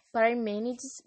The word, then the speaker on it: Parmênides: Deixa eu ver se eu falei certo. Ó, Heráclito, do time mobilismo, e Parmênides, do time imobilismo. Eu não tenho certeza se eu falei certo, mas essa última foi certa, eu dei uma olhada.